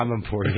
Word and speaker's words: Amanpour: What's the how do you pronounce Christiane Amanpour? – Yeah, Amanpour.